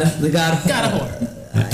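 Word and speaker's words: Hoar: It's H-O-A-R, but they say it's pronounced whore.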